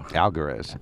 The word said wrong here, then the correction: Almaguer: Alguerez, right. Really, it's not spelled that way. It's spelled Almaguer.